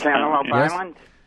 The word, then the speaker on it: Cantaloupe Island: Or Cantaloupe Island, as a matter of fact. – Cantaloupe Island.